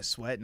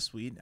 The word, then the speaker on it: Sweat: Yeah, because I was over here like, well, I mean, I'm a Montez Sweat guy as well, but I'm like, nah, or excuse me, yeah, Sweat, not Sweet.